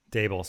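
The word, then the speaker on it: Daboll: Interviews have been had with Robert Saleh, Marvin Lewis, Joe Brady, Brian Dabble, Eric Bieniemy. Dable, sorry, I did it again.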